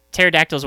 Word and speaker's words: pterodactyl: So I'm not upset about the silent K, but the silent P in pterodactyl, I'm pretty upset about.